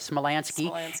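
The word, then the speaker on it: Smilansky: Did I say it right? Except not slow like that. That's not quite right. Smolansky.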